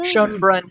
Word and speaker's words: Schönbrunn: Schönbrunn.